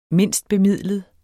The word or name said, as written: mindstbemidlet